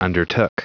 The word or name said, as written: undertook